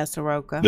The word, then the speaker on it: Saroka: And the victim kept asking Saroka, or however you, the hell you pronounce his name. – Yeah, Saroka.